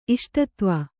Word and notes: ಇಷ್ಟದೈವ: kannada

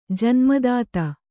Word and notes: ಜನ್ಮದಾತೆ: Kannada

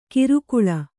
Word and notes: ಕಿರುಕುಳ: kirukuḷa

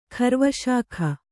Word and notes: ಖರ್ವಶಾಖ: Kannada

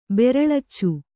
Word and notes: ಬೆರಳಿಚ: beraḷica

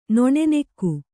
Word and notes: ನನ್ನಿಗ: Kannada

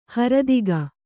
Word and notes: ಹರದಿಗ: haradiga; Kannada

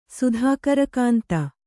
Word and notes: ಸುಧಾಕರಕಾಂತ: sudhākara kānta

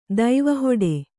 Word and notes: ದೈವಹೊಡೆ: Kannada

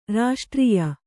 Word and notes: ರಾಷ್ಟ್ರೀಯ: raṣṭrīya

